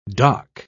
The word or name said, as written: dock